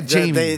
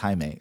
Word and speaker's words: Jaime: who plays Jaime Reyes in the Blue Beetle. – Jamie? – From Cobra Kai. Yeah, Jamie. – They kept saying that, and he's like, it's Jaime. – Jaime, yeah.